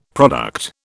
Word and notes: product: British English

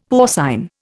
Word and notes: porcine: Indian

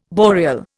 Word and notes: boreal: Indian

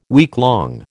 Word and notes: weeklong: American English

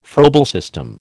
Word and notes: Froebel system: American English

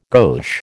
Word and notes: gauche: American English

